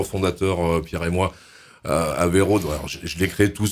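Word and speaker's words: Avey Rode: Averode, ça s'écrit A-V-E-Y, on pense à Aveyron, voilà. – Même le R. Puis il y a Rode, pour route en anglais.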